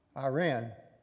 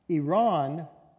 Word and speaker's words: Iran: Iran is closer to how the native people pronounce it, and Iran is common Americanized pronunciation.